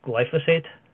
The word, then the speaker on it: glyphosate: First one is the use of glyphosate. Am I pronouncing that correctly? Is it glyphosate? Yes, okay.